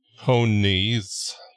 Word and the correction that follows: ponies: There's not emphasis on both syllables of ponies. It's just ponies.